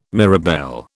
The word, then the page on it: mirabelle: British English